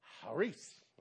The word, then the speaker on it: charis: So if you have a daughter named Charis, don't go home and call her charis because people think she's crazy. But in the cultural meaning, the name, the word is pronounced charis, okay? And it means unmerited favor of God. That's what grace, charis, or charis means in the original Greek.